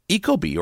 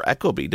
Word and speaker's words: Ecobee: Uh, another one is Ecobee or Ecobee, depending on how you want to pronounce that, have just released two new versions of their smart thermostat.